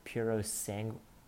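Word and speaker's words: Purosangue: It's been called the Puro Sangue.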